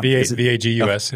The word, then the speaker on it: vagus: I always want to call it Vegas nerve, like the city Vegas, but that's it's not. – Vagus.